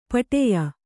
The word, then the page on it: ಪಟ್ಟೆಯ: paṭṭeya